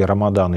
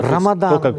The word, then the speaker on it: Рамадан: Или Рамадан еще, да? – Но Рамадан или Рамазан. – Кто как произносит.